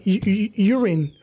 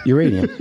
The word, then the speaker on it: uranium: No, because sometimes in English I pronounce it like urine. I was like, oh. – Yeah, you can't say urine. – Yeah, you see. – No, it's uranium.